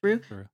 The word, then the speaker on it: Saru: Saru. Am I saying that right? Saru? – Yep. – Saru.